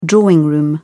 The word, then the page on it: drawing room: British English